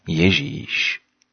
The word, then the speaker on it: Ježíš: Česky to vyslovujeme Ježíš.